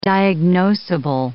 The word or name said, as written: diagnoseable